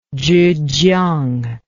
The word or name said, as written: zhejiang